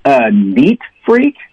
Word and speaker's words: neat freak: 应该是这样的对吧、今天就问到说哎他有洁癖吗 Is he a neat freak? – 好了今天教大家一个很炫的短语、mm-hmm. 叫做一个有洁癖的人。 – A neat freak.Freak. Yeah, stress the first word, a neat freak.